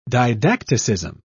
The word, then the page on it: didacticism: British English